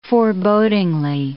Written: forebodingly

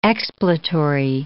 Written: expletory

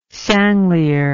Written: sanglier

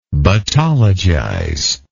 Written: battologize

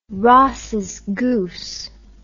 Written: Ross's goose

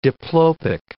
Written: diplopic

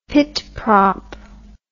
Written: pit prop

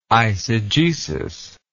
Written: eisegetical

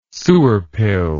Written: sewer pill

